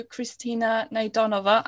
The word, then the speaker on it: Kristina Naydonova: Today, I have the great privilege of talking to Kristina Naydonova.